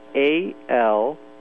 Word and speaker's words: Alonsoa: The one that I can't seem to figure out is the Alanzona. – Yeah, why don't you spell it for us? – It's Alonsoa.